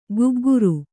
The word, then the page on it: ಗುಗ್ಗುರು: gugguru